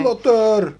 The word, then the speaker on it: Slaughter: Slaughter, Slaughter, nie wiem jak się wymawia. Slaughter!